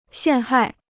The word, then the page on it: 陷害: xiàn hài